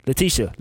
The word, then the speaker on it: Letitia: You got Tiffany Haddish, Angela Bassett, Issa Rae, Taraji P, Latia. Is that how you say her name? – Letitia. – Letitia Wright. Okay, cool.